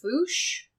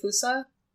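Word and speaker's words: Fossa: So, the indigenous pronunciation seems to be foosh, but the widely accepted pronunciation seems to be Foosa.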